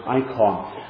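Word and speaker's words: icon (εἰκών): The word image in English is the Greek word icon. That's how we pronounce it, icon.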